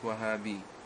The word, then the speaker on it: Wahhabi: In the 20th century, the most commonly used epithet for describing such an apostate has been the label Wahhabi, pronounced Wahhabi.